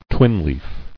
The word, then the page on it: Twinleaf: twin·leaf; Twin"leaf`